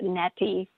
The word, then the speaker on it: neti: So the ancients knew this, and they developed a practice called neti, N-E-T-I, okay? So, and you could call it neti or neti. E's in Sanskrit are pronounced A.